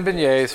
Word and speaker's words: beignets: So, Blake's making beignets.